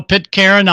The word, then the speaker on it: Pitcairn: And then Renrat also wants to know if you've ever contacted Pitcairn Island. – Oh, yeah. – I'm not sure if I'm pronouncing that right. – No, Pitcairn Island, yes.